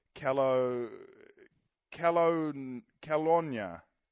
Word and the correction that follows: Kelowna: Calonia, Calonia, Kelowna. – Kelowna. – Kelowna.